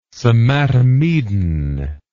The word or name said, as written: somatomedin